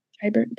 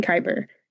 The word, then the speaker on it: Khyber: He worked in Khabar, Khabar. How do you say that one? – I think Khyber. – Khyber. That makes more sense. That would not work in Khyber.